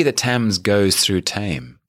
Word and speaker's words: Thames: You know, English is confusing. There are silent letters in it, and you know, yes, Thames. – Thames. – It should almost. How would you spell Thames? – T-E-M-M-S.